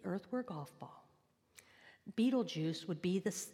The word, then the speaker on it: Betelgeuse: And it really is pronounced Betelgeuse.